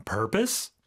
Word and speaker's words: purpose: 자, 이거 purpose라고는 발음하지 않습니다. P-O-S-E로 끝나긴 하지만 비록 이걸 purpose라고 하면 안 되고요. 우리가 propose 할 적에는 os라고 하는데 purpose 같은 경우는 강세가 앞에 오기 때문에 어떻게 할까요? – Purpose. – Purpose. – Us, us, purpose. – Purpose, 그렇죠. – Purpose. – O 발음이 아니라 발음으로 purpose라고 하게 됩니다.